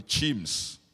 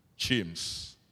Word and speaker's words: Chims: But to make it short for people who have got a problem to pronounce Chibangu Mukumbai, you can just call me Chims. So like chips, Chims.